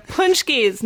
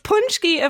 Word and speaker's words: pączki: Yeah, Punchkeys, not spelled like they sound at all, because Polish people are awesome that way. – I love Punchkeys. – So a Punchki, of course, is a delicious and incredibly rich filled jelly donut.